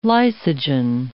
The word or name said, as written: lysogen